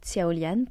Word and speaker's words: Xiaolian: Alors tout d'abord, je m'appelle Léa, ou Tiaolian pour les amis de longue date. Donc ça s'écrit X-I-A-O-L-I-A-N comme mon pseudo Insta ou TikTok ou encore Spotify, je ne sais-je.